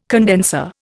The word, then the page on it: condenser: Indian